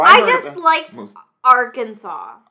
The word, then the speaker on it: Arkansas: So it's like a little bit tricky how it's read because like no one ever pronounces the S. So I actually always spell it wrong because it's Arkansas.